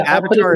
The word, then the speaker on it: Awatar: But yeah, Avatar is spelled with a W, AWATAR. Not with a V, it's with a W, Avatar, really.